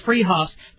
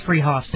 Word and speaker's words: Fruhoff's: It's spelled Fruhoff's, pronounced Freehoff's. They're at 33rd and Arapahoe in Boulder. That's Freehoff's.